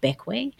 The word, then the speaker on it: Bequia: It's spelt Bequia, but it's actually pronounced Beckway.